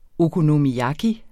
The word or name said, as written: okonomiyaki